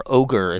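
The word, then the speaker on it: Oger: I mean, were they, because I think you were even sued by a competitor, I don't know if I'm pronouncing it right, but it's O-G-E-R, Ogre is how to pronounce it.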